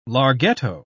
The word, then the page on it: larghetto: British English